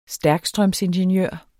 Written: stærkstrømsingeniør

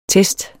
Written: Testene

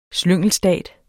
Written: slyngelstat

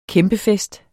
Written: kæmpefest

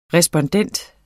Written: respondent

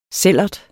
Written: sællert

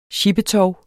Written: sjippetov

